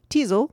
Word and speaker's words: Teasel: Teasel, which rhymes with weasel, does not.